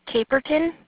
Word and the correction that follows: Cape Breton: Cape Breton.